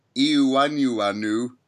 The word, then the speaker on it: Iwuanyanwu: Uh, then he was at, and this is the one I'm going to struggle with, Iwuanyanwu Nacional.